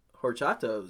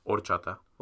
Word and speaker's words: horchata: Horchata was good. – Horchata. – Horchata was good. – Don't pronounce the R. No, H, excuse me.